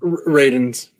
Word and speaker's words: Radunes: So I think that there's really not a huge, while they are losing some guys like Radunes, is that how you say his name? – Radunes. Yep.